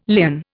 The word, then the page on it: lien: Indian